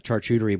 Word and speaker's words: charcuterie: Well, I think they're actually going to eat cheese and have charcuterie boards.